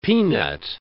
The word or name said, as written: peanut